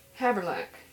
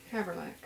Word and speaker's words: Havrilack: Well, your name is spelled Havrilack, and you pronounce it Haverlack. – Haverlack. Yeah.